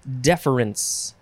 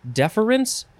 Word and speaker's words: deference: Next word, deference. Uh, you can have three syllables or two syllables. Deference or deference.